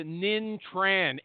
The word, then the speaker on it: Ninh Tran: If you want a demo, reach out to Nin Tran. N-I-N-H-T-R-A-N. There's that silent H. I tell him to get rid of it, but he won't.